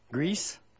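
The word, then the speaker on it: Greis: But it's Randy Grief. – Grease? – Yeah, it's like he has like a Alice in Wonderland.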